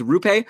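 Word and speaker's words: Roope: But apparently it's Rupe, just like Lupe, the cleaning lady who George hooked up with on Seinfeld.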